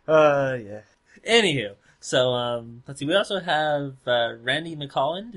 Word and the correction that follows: Milholland: Is that how you say his name? – Milholland. – Milholland? – I believe it is.